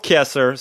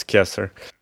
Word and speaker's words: Kieser: And the band is called Caesar. Caesar? Caesar? No, it's called Kieser, sorry. In the beginning, there were a lot of organizers and people confused about how you pronounce their band name, but it's Kieser.